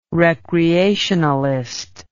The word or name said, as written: recreationalist